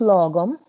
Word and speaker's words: Lagom: Lagom, The Swedish Secret of Living Well. And Due North. What does Lagom mean? – So it's pronounced Lagom, and it is a Swedish kind of philosophy or ethos of living.